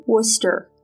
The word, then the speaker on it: Worcester: She attended Assumption College in, I'm going to mispronounce this, Worcester? – No, Worcester.